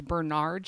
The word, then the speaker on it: Bernard: So Pygmalion is a play by George Bernard Shaw. And I would just like to point out that my high school theater teacher was very adamant about correcting us if we pronounced it George Bernard Shaw.